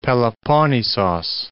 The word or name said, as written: Peloponnisos